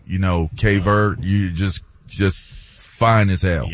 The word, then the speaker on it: Cabo Verde: I'm seeing, by the way, it's pronounced both ways. It's pronounced Cape Verde or Cape Verde, but the Portuguese pronunciation is Cabo Verde.